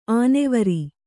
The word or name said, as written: ಆನೆವರಿ